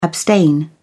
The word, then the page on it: abstain: British English